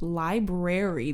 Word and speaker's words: library: It's pronounced library. There's an R in there, dipshit.